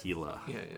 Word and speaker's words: Gila: Great album, Hila Beef. – I liked it a lot. – That's spelled G-I-L-A, Hila Beef.